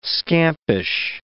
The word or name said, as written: scampish